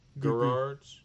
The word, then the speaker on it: Gerard: It's a hard G. – Gerard. – Gerard's.